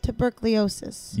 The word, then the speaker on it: tuberculosis: I don't know if you know, but Satina's dying of consumption tuberculosis. How do you say that word? – Tuberculosis.